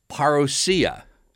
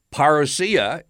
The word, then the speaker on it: parousia: The Greek word behind coming is parousia. And it's such an important word that in English, the word parousia is spelled just like the Greek word, P-A-R-O-U-S-I-A.